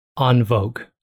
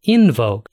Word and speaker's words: En Vogue: The letters E-N in American are pronounced in because they're fucking stupid. And so they, all of them, the judges, the band, Nick Cannon, screaming, calling Ken Jeong an idiot because who says En Vogue?